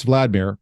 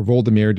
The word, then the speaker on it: Vladimir: So Prince Vladimir, or Voldemir, depending on how you pronounce it, he was the ruler of Kyiv.